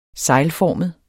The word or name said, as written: seglformet